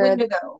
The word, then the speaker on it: Wendigo: Or Wendigo, I'm not sure. – Wendigo. – Wendigo. – Wendigo? – Wendigo. – So that's how you pronounce it.